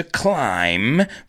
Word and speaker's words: climb: And I had to climb, remember that B is silent, es una B muda.